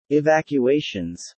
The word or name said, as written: Evacuations